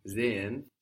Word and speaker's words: sehen: The S on sehen sounds like a Z. And that's what happens when we have an S and a vowel starting a syllable.